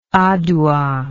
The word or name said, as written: Adowa